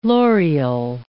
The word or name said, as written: loreal